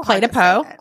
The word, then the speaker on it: Clé de Peau: So Clé de Peau.